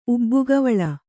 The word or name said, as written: ಉಬ್ಬುಗವಳ